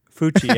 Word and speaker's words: Fulci: I got another Fluchi. Fluchi, right? How do you say it? – Fluchi. Fluchi. – Fluchi? Fuchi? – Fuchi, yeah. Okay. F-U-L-C-I.